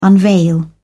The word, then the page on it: unveil: British English